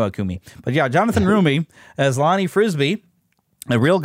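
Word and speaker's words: Roumie: Did I say it right? – I thought it was Rumi. – Is it Rumi? Let me look him up again here. It's on my page. Yeah, it is Rumi.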